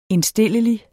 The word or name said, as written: indstillelig